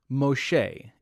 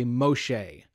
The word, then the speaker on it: Moshe: So she adopts this baby, and she names the baby Moses, or Moshe is like the Hebrew pronunciation, I think. Everyone say Moshe.